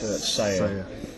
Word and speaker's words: Sella: It's actually spelled S-E-L-L-A, but it's pronounced Sayer.